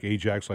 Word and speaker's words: Ajax: It's spelled A-J-A-X. It looks like Ajax, like the dishwashing detergent, but it's pronounced Ajax.